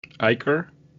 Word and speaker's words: ichor: I don't want to give away how I've, the word is I-C-H-O-R. – Icher? – Yes, you are saying it correctly.